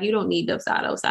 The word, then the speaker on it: Dubsado: A lot of people always say Dubsado. I think that's how you say it. – Dubsado. – Yeah.